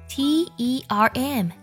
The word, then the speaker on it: term: 比如，我们来看几个单词：小鸟 b i r d 读作 bird bird；学期 t e r m 读作 ter ter term；世界这个单词 w o r l d 读作 world world world world。